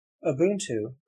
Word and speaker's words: Ubuntu: Ubuntu, pronounced Ubuntu, is a sub-Saharan African ethic or ideology focusing on people's allegiances and relations with each other.